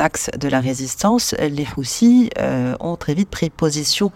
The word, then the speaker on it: Houthis: Elle m'a expliqué les motivations des Houthis qui se prononcent en réalité avec un S en arabe. Les Houssis euh,